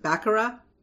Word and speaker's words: baccarat: One of which is, how do you pronounce it? Baccarat? – Sure.